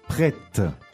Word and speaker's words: prêt: Prête.